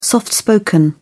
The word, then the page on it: soft spoken: British English